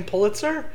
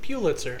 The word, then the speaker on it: Pulitzer: I just found that really weird that there's apparently a whole another sphere of human beings that pronounce it Pulitzer. – It's like pugilist.